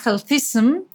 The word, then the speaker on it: healthism: pero que se llama healthism. Eh, lo dije bien, Pau. Sí, está bien.